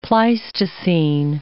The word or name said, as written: Pleistocene